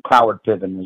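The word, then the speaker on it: Cloward-Piven: They get them to replace us, um, and they get them voting, and that's called clover Piven. And or uh, Cloward Piven, as some people pronounce it.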